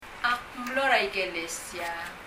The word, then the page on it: ikelesia: ikɛlɛsiə